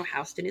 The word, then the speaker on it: Houston: And if you've never been to New York, you know Houston is spelled just like the city Houston, but not pronounced like Houston.